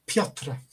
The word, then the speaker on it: Piotr: It's the irony of it for me, because when Petr Yan, I've probably just butchered that name, it's Piotr Yan. Yeah, you say it beautifully. Just say that again for everyone who didn't hear you. – Piotr. – There you go, spot on.